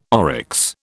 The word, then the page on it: oryx: British English